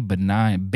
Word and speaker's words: banal: It's a really banal. It's really a banal, but yes, that's how you say it. Banal.